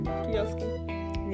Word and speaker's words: Kiyosaki: Rich Dad Poor Dad by Robert Kiyosaki. Is that how you say it? Kiyosaki? Yeah.